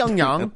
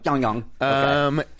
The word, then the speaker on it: Pyongyang: Pyongyang. – Pyongyang.